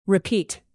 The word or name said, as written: repeat